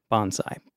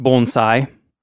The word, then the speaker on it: bonsai: that's what pretty much every American, I think, calls these little trees bonsai. But uh, in Japanese, it would be pronounced bonsai.